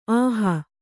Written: ಅಹಿ

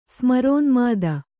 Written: ಸ್ಮರೋನ್ಮಾದ

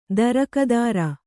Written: ತೇರ್ಗುದುರೆ

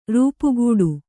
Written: ರೂಪುಗೆಡು

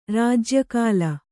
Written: ರಾಜ್ಯಕಾಲ

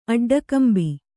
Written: ಅಡ್ಡಗಂಬಿ

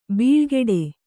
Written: ಬಾಳುಗೇಡಿ

